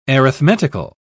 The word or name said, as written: arithmetical